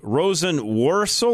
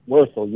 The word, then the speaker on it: Rosenworcel: Rosenworcel or Werkel? How do I say that? – Werkel. You nailed it the first time.